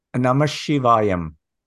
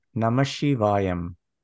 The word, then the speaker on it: Namasivayam: Okay, so it is Namashivayam. – Say that one more time. – Namashivayam.